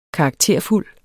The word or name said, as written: karakterfuld